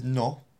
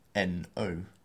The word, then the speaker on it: no: And this is no, N-O. So we don't pronounce it like we do in English, which is no. It has to be a much more pure vowel sound.